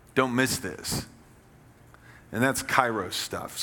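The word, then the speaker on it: kairos (καιρός): The word opportunity there is that's the ancient Greek word pronounced kairos.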